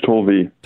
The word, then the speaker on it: Tolve: Is it Tolve? – Is that it? Right? Tolve. – Tolve, excuse me.